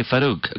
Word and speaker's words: Farouk: I like the way you say Farouk.